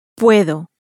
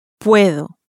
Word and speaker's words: puedo: Puedo. Puedo. – It ends in a vowel, and so it receives its stress on the next to last syllable.